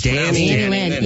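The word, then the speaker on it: Dannielynn: It actually is one word. – Oh, really? But everyone says but Danny Lynn. Yeah, it's pronounced Danny Lynn.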